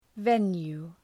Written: venue

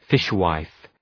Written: fishwife

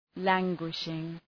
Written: languishing

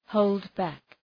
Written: hold back

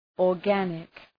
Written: organic

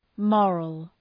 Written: moral

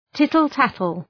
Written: tittle-tattle

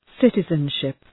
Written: citizenship

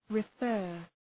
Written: refer

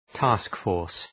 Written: task force

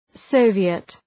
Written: Soviet

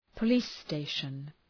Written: police station

